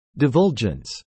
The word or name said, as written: divulgence